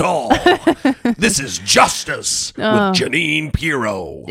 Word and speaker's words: Jeanine: Jeanine.